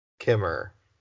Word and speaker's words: Kammerer: Yeah, Jake, J-A-K-E, obvious. K-A-M-M-E-R-E-R. Kimmer. Yep, that's me.